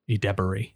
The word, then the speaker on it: Edebiri: Rachel Sennott and 2023 It Girl, Ayo Edebiri.